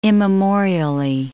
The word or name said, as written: immemorially